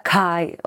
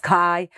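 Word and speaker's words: K'aay: En realidad se escribe K apóstrofe A-A-Y, pero... – Un nombre místico, fuerte. – Sí, y se pronuncia Kai. Bueno, creo que me van a matar los yucas, porque seguro no me sale muy bien, pero es Kai.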